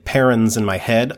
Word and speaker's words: Paruns: I say it Perrins as well. – Oh, this is just me being awful. Okay. – All right, you're outvoted. It's Perrins.